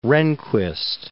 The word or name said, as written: Rehnquist